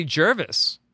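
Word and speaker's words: Gervase: Well, and we were saying last night, like, the interesting thing about that to me was how do you say Gervais or Gervais? – I say Jervis. – Jervis. And so neither. Jervis. Okay.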